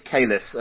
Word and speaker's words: Kalis: I want to say Cullis, like the cricketer Jacques Cullis, but it looks like Kalis. What do we know about that business? – Yeah, it's Kalis.